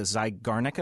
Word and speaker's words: Zeigarnik: I want to ask you about tell me if I'm pronouncing this correctly, the Zeigarnik effect. – You're pronouncing it perfectly.